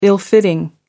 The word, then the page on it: ill-fitting: American English